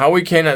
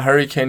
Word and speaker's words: Hurricane: Nein, Howie Kane, also habe ich das früher manchmal gesagt, weil viele Leute, die ich kenne, immer Hurricane nicht aussprechen konnten und immer Howie Kane gesagt haben.